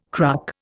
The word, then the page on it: cruck: Indian